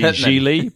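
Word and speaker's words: Gigli: It's Ben Affleck plays a hitman named Larry Zhili, pronounced like really, which he has to address.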